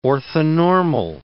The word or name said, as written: orthonormal